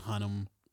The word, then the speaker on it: Hunnam: Uh, we have Charlie Hunnam. Is that how you say his name? Yep.